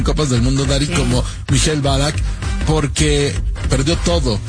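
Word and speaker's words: Michael Ballack: ¿Cómo se dice bien? ¿Mikhail Ballack? – Mikhail Ballack, sí.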